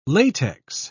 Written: latex

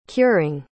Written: curing

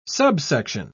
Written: subsection

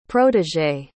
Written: protégée

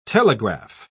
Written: telegraph

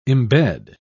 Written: embed

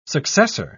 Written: successor

